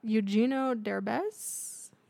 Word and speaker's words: Eugenio Derbez: Eugenio Derbez.